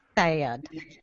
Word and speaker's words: Thad: Thad. – Well, if you got to have the drawl too.